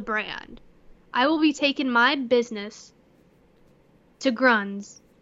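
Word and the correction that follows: Grundens: Did I pronounce Grundins. – It right? – Grundins. – Grundins, sorry.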